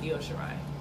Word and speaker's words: Io Shirai: Yeah, I didn't know it's pronounced Io Shirai. From is like, your friend keeps saying low, it's Io.